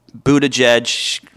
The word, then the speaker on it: Buttigieg: Mayor Pete is, it's very clear, Buttigieg, however you say his name. It is exactly right.